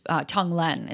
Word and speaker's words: Tonglen: And one of them that I talked about was the Tonglen breathing, which I've gotten a lot of emails from people saying that it's Tonglen is how you say it.